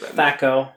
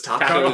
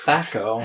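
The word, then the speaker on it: THAC0: Yeah, the person I played with, like the GM, they thought it was like a silent H. – So it was Taco. – It was Taco, yeah. – No, it's Thaco.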